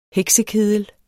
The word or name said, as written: HEKSEKEDEL